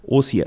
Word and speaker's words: OCI: Also äh, wie Sie schon äh, eingangs richtig gesagt haben, OCI, äh, also im Englischen ausgesprochen, auf Deutsch geschrieben natürlich OCI, Otto Cesar Ida, wie ich es immer buchstabiere, wenn mich jemand fragt, ähm, Dahinter steckt Outlet Centers International.